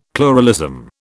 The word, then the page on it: pluralism: British English